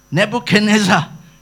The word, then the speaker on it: Nebuchadnezzar: Sometimes they say you forget how to pronounce Nebuchadnezzar. Just remember Jalaksa, you know. Jalaksa. Nebuchadnezzar.